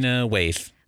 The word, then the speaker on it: Lena Waithe: Lena Waithe. – Lena Waithe,